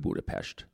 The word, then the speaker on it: Budapest: We have to include Budapest.